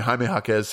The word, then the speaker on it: Jaime Jaquez: Jaime Jaquez. – I don't have his name in front of me. Jaime Jaquez sounds sounds right.